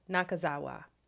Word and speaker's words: Nakazawa: I want to pronounce it correctly. I just practiced. Nakazawa. I think that's how you do it.